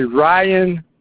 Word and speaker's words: Ryan: You can ask for Ryan Chase. My name is Ryan Chase, and I will be. – How do you spell that? – It's R-Y-A-N, Ryan, C-H-A-S-H.